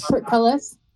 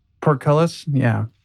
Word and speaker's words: portcullis: Portcullis. – Portcullis, yeah.